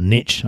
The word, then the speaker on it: niche: And I think the money is actually finding a niche, or a niche, I believe, as it's pronounced.